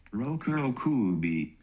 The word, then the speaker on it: Rokurokubi: It's spelt like Roku, like the Roku that we all use on our lovely streaming things. – Yeah. – Roku, Rokubi.